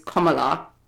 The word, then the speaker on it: Kamala: And by the way, we realized that we mispronounced her name a few times here, but we wanted to put some respect on her name. So the real pronunciation of her name is Kamala.